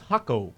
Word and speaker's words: Jaco: But it's spelled J-A-C-O, the name of the city. The beach town in Costa Rica is Hocko.